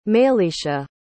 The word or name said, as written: mayliesha